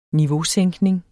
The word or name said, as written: niveausænkning